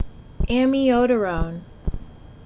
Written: Amiodarone

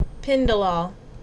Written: Pindolol

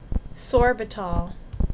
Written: Sorbitol

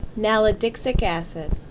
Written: Nalidixic Acid

